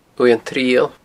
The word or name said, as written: uaimh an triall